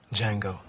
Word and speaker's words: Django: Django. – The D is silent.